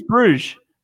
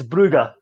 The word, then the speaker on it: Brugge: It is Bruges. – It's Brugge. – It's Brugge. – Well, it is Club Bruges. – His great pronunciations. He butchers the easiest one on the books. – Nah, I'm standing with Club Bruges. – Well, you're sitting and you're wrong.